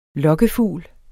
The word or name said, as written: LOKKEFUGLEN